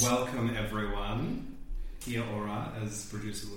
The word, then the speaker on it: Kia ora: Kia ora.